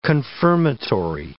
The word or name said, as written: confirmatory